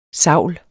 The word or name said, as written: savl